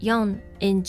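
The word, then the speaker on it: 四人: For instance, 4 is pronounced yon in general, but for people in Japanese is yonin, so we don't pronounce it as yon.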